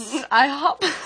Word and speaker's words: IHOP: IHOP.